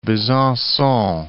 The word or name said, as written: Besancon